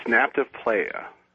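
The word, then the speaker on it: Nabta Playa: Tell me the significance of that near, Napla, Playa? Is that how you pronounce it? – It's Napta Playa.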